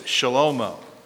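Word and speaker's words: shalomo (שלמה): In Hebrew, it's shalomo. That's how you'd pronounce Solomon.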